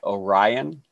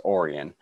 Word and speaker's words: Orion: It's spelled like Orion, like the stars, but it's pronounced Orion.